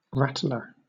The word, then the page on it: rattler: British English